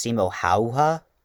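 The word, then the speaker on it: Simo Häyhä: And, while his name is technically pronounced Simo Hauha, in his native tongue of Finnish, it's really hard to say, you know, for me not knowing Finnish, and I don't want to butcher it, so I'll just stick with the non-Finnish pronunciation, by saying Heha.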